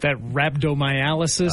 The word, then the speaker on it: rhabdomyolysis: Is that rhabdomyolysis? – That's exactly what that is.